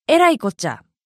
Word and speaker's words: えらいこっちゃ: 大変だという時は、えらいこっちゃ、えらにアクセント。